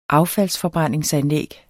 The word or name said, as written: affaldsforbrændingsanlæg